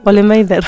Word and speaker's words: Vollenweider: ¿Cómo se pronuncia? ¿Vollenweider o algo así? – Sí, creo, Vollenweider.